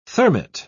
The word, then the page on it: thermit: British English